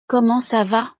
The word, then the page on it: comment sa va: frances